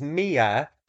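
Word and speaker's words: Mea: So my name is Mia T, T Cleaver.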